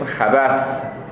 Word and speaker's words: خبث: با البته باید بگیم خبث یعنی سه نقطه داره، نه خبث که ما میگیم ممکنه بعضی با سین اشتباه بگیرن دیگه تلفظ رو.